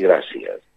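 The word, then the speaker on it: gràcies: Es una que se parece un poco, se escribe como una E, pero en catalán tenemos vocales neutras y esta es una de estas neutras. Está entre la A y la E. Gracias. Mil gracias.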